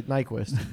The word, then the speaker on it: Nyquist: I mean, Panarin had that one goal that was handed to him by Nyquist. – Nyquist. Nice. – I mean, that is how you say Nyquist.